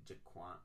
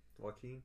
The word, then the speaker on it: Joaquin: Is it Jaquan? Is it, I don't. – Joaquin.